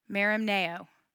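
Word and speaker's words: Merimneo (μεριμνάω): Merimneo.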